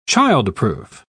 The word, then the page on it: child-proof: British English